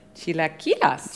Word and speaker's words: chilaquiles: Chilaquiles.